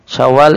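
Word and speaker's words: Syawal (شوّال): Syawal itu tasdidil waw, wawunya ditasdid.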